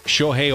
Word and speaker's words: Shohei: Shoshone Ohtani? – Yeah. Shohei Ohtani. – There we go.